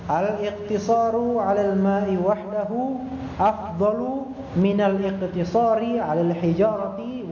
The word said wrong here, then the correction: wahdihi: Afwan, salah tadi saya bacanya, al-iqtisaru 'alal ma'i wahdihi afdalu min al-iqtisari 'alal hijarati wahdaha. Begitu yang benar.